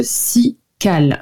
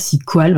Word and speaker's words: Ciqual: Donc c'est le si Cal, si Cal, si Qual, voilà, vous prononcez comme vous voulez, ça s'écrit C-I-Q-U-A-L,